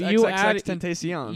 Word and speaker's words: XXXTentacion: Yeah, I'm pretty sure it's XXXTentacion.